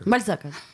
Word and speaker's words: Бальзака: Я взял Бальзака или Бальзака? Бальзака.